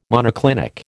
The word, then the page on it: monoclinic: American English